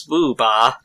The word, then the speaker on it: Boober: Known in Japan as Boober. According to the romanization on this encyclopedia, it's the original characters would be pronounced as booba, but anything that ends in ah, they add the R to, as if it's like a British accent. When they romanize things.